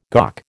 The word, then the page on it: GOC: American English